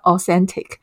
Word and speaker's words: auscentic: 这个字不是我发音不标准，你想说，哎，你是要念 authentic，舌头要伸出来，不是，它就是真的叫 authentic，它是 authentic 加上 scent 的一个组合字嘛。好，我们邀请到的是这个 authentic 的香气主理人 Freddy 来跟我们聊聊关于香气、香氛，还有为什么天然的香气这么让人家觉得舒服。我们欢迎 Freddy。